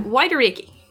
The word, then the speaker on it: Waitoreke: And I think I can only really pronounce it correctly by trying to do a bad, like, Kiwi accent for a half second. But Waitoreke. Or Waitoreke, or Waitoreke.